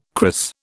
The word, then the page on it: cris: British English